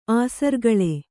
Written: ಆಸರ್ಗಳೆ

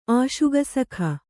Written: ಆಶುಗಸಖ

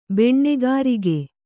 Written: ಬೆಣ್ಣೆಗರಗ

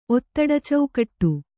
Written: ಒತ್ತಡಚೌಕಟ್ಟು

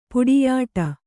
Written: ಪುಡಿಯಿಡು

